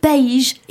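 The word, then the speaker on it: país: Par exemple, les mots euh, qui veut dire les parents, sans accent tonique, euh, et par contre, si on met un accent sur le i, ça fait paige. Et paige, c'est le pays.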